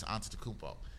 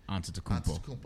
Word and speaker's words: Antetokounmpo: But after 60 Minutes had another piece after that that focused on Milwaukee Bucks star Giannis Antetokounmpo.